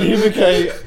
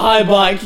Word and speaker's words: Hibike: Hibike Euphorium. – I said High Bike. – Yo, you're telling me it's called Hibike Euphorium, not High Bike Euphorium.